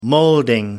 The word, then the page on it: moulding: American English